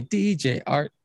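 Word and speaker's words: DJ Artt: DJ Art, with two T's for a double dose of that tink tink. The D is silent, so it's just Jart.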